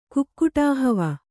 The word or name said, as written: ಕುಕ್ಕುಟಾಹವ